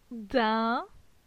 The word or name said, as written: Din